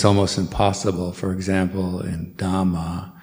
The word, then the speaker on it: dhamma: And also try to pronounce all the H's. So dhamma, buddha, sangha.